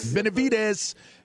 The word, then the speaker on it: Benavidez: Benavidez and my man Caleb Plant, they're going to get it on tonight.